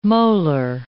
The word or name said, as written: molar